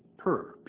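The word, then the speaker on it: pur (πῦρ): In fact, the Greek word used here for fire is pur. They spell it P-O-O-R, or it's written P-U-R. I'm getting a testimonial here from the Greek. It's a pur. It is related to our word purify.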